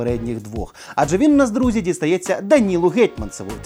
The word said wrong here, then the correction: Гетманцеву: точніше, Гетманцеву. Е, його ж Гетманцев, да, правильно говорити? – Ну, Пост- так. Постійно плутаю. – Ну, хай буде Гетманцев.